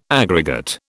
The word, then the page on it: aggregate: British English